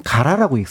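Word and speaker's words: 가라 (唐): 아, 그런데 그 당을 일본에서는 가라라고 읽습니다.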